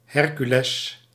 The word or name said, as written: Hercules